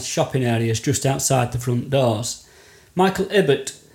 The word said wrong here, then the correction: Ilbert: is it Ibbett or Ilbert? – Ilbert. – Have I been saying it wrong all this time?